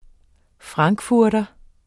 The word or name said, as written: frankfurter